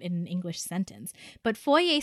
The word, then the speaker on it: foyer: Americans pronounce foyer as foyer, and that pisses me off. – Yes. It's foyer. Foyer. – No, yeah, it's a French word. – You're French. – It's a foyer. – Yeah, you're a French fuck. – It's a French word, so it has to be pronounced French.